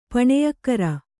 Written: ಪುಣ್ಯಕಾರಿ

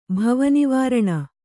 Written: ಭವನಿವಾರಣ

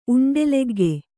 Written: ಉಂಡೆಲೆಗ್ಗೆ

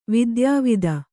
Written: ವಿದ್ಯಾವತಿ

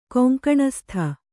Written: ಕೊಂಕಣಸ್ಥ

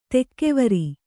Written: ತೂಗುವರ

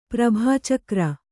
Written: ಪ್ರಭಾಚಕ್ರ

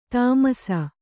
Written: ತಾಮರಸ

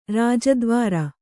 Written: ರಾಜದ್ವಾರ

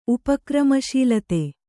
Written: ಉಪಕ್ರಮಶೀಲತೆ

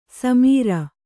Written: ಸಮರು

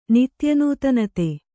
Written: ನಿತ್ಯನೂತನತೆ

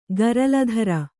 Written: ಗರಲಧರ

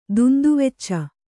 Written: ದುಂದುವೆಚ್ಚ

